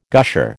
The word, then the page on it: gusher: American English